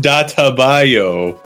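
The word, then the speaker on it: Dattebayo: All right, real quick, it's pronounced Databayo, but Databayo.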